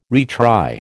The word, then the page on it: retry: American English